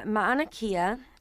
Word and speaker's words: Mauna Kea: It's the Mauna Kea.